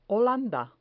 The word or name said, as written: holanda